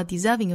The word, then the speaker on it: deserving: 我们在 merit 这个位置用升调，最后呢 deserving 用降调读出来。 Oft got without merit and lost without deserving. Oft 美音读成 oft， got 美音读成 got， lost 美音读成 last。 Deserving, 美音读 deserving. 注意字母 S 同样读浊音的 z, deserving.